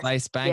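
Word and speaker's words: Claes Bang: And I think why I said with your film that I didn't really know where it was going is because with, and I'm going to make sure I'm pronouncing it correctly, Klaes Bang. Is that right? – Yes. – Klaes Bang.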